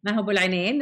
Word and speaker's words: Maha Abouelenein: Maha Abouelenein, that's pretty good.